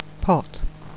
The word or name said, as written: pot